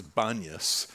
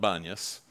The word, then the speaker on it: Banyas: They come down to the bottom, and of course, down at the bottom of the mountain, Caesarea Philippi, known today as Banyas, it gets that word from, because in the Arab language, you can't pronounce the P. So it really should be Panyas, as in the demon god Pan, because it was a site of great Pan worship.